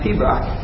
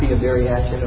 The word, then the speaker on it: Pibroch: It's pronounced Pibroch, not Pibariachit or something like that.